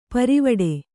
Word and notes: ಪಾರಿವಟ್ಟೆ: pārivaṭṭe

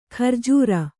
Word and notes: ಖರ್ಜೂರ: kharjūra

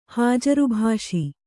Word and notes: ಹಾಜರ್‍ಭಾಷಿ: kannada

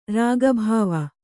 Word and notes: ರಾಗಭಾವ: rāga bhāva

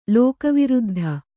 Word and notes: ಲೋಕವಿರುದ್ಧ: lōka viruddha

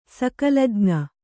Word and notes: ಸಕಲಜ್ಞೆ: kannada

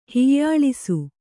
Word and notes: ಹೀಯಾಳಿಸು: hīyāḷisu

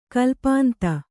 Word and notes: ಕಲ್ಪಾಂತ: kalpānta

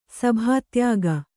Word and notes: ಸಭಾತ್ಯಾಗ: sabhātyāga

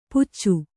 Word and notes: ಪುಚ್ಚು: puccu

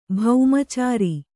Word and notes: ಬೊಮ್ಮಚರಿ: bommacari

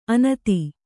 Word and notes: ಅನತಿ: kannada

